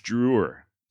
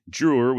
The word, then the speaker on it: Drouillard: It is French, spelled D-R-O-U-I-L-L-A-R-D, and apparently the D is silent. I went back and forth on whether to use Drewer or Drewyar, so you may end up hearing a mixture of both during this series.